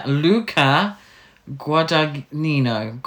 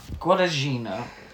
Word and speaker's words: Luca Guadagnino: Can you say this director's name so we get the pronunciation right? – Yeah, Luca Guadagnino. Guadagnino.